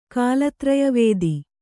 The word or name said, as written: ಕಾಲತ್ರಯವೇದಿ